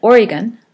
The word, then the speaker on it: Oregon: Okay, so that's in Oregon, which Kari told me how to say, not Oregon.